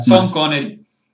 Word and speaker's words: Sean Connery: O sea, no da la sensación de que los actores no sepan actuar, se ve mucho sobre todo con Sean Connery, da la sensación de que las instrucciones son extrañas. De que lo del director te lo... – Pero pronuncia son, por favor, no lo Son Connery. Venga.